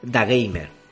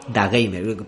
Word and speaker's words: DAGMR: pues entrevista a Carlos e Íñigo de Da Gamer, que se escribe DAGMR, eh, D-A-G-M-R. Da Gamer, parecido a Da, da ese de deportes, Dazone, el canal ese de, televisión de deportes, pues pareció Da Gamer.